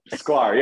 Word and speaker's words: Sklar: Sklar, right? – Sklar, yeah.